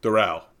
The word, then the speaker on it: Doral: Doral. – What did I say? Doral? – Yeah. – Yeah, you rhymed it with coral.